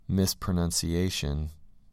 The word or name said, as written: mispronunciation